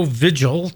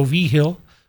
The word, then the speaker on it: Vigil: And so the name Joe Vigil to most people, but it turns out it's pronounced Joe V. Hill. It's a Latino name, is the most successful track and field coach probably in the history of the world.